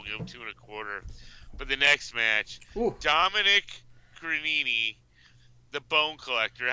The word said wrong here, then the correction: Guarini: Did I say his name right? – Guarini, I think is how it's said.